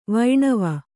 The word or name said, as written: ವೈಣವ